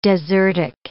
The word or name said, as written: desertic